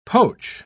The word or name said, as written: poach